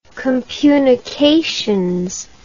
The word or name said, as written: compunications